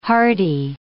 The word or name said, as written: hardy